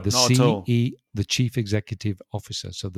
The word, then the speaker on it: CEO: We actually specifically mention or pronounce letter individually. – Each letter. That's right. – So we don't make it into a word. CEO. – Yeah. – You say CEO, which is more difficult because it's slower. You have to pronounce each letter.